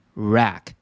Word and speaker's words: wreck: 好，我们列出以下四个同义词，像是第一个 wreck，这里的 w 呢不发音，以及 ruin、 shatter，还有最后一个 spoil，这些呢都可以表达破坏、损坏的意思，都是 ravage 它的一个同义词。